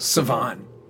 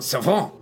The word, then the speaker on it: Cavon: So Savon. – Like the French pronunciation. – Savon.